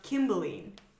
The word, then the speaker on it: Cymbeline: It's set in Roman Britain, so it probably would have been pronounced Kimbeline because C was not soft at that time.